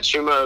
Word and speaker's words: Chuma: How do you say his first name? Chuma? – Yeah, Chuma Okiki.